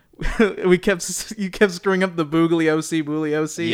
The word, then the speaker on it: Bugliosi: And he's like, Bugliosi. – Yeah, the G is silent.